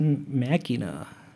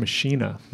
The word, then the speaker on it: Machina: Okay, well, the album after that is Machina. – And I think you're right, that's the correct pronunciation of that word, but I've always heard Billy refer to that album as Machina.